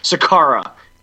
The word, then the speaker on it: Sekera: Carolina defenseman Andre Sekera prefers to have his last name now pronounced Sakara.